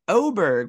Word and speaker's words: Åberg: That did happen this last weekend, as Ludvig Åberg, which is actually how it's actually pronounced, not Åberg, like we've been saying this entire time, gets his first professional win on the PGA Tour.